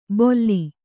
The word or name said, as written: ಬೊಲ್ಲಿ